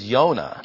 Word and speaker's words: Yonah: Micah in Hebrew is Micah. Jonah is Yonah.